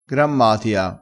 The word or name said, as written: grammatia